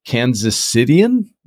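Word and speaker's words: Kansas Citian: I'm going to assume that you're a native Kansas Cityan. Is that how you say it? – You nailed it.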